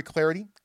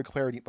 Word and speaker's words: Clardy: Follow me up on Twitter at Troy Clarity, at Troy Clarity. The last name is spelled C-L-A-R-D-Y.